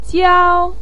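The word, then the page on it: jiao: tone 1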